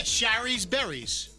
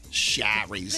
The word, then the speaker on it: Shari's Berries: Yeah, Shari's Berries. – Sherry's. – It's supposed to rhyme, isn't it? – Berries.